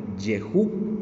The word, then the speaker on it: Jehú: Y también hay otro que, se menciona en 2 de Crónicas 20:34 de nombre Jehú, que se escribe J E H U, acento en la U les leo, hermanos, que dice eh, segundo de Crónicas 20 al 34.